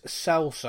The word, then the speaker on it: Celso: Um, on our first show, I was saying La Celso, uh, La Chelso. I wasn't getting it. It is Celso.